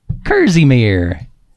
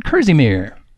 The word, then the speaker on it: kerseymere: Kerzymere. And Dozymere and little lambs eat Mirvy. It's spelled K-E-R-S-E-Y-M-E-R-E. Kerzymere. Oh, won't you please send me those definitions for the word Kerzymere now.